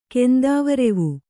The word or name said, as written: ಕೆಂದಾವರೆವೂ